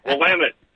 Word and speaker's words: Willamette: Hey, John, the former radio voice for years for the Willamette Bearcats, better known as Willamette, damn it.